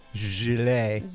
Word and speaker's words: gelée: Gelée. It's like jelly, but pronounced with a French way.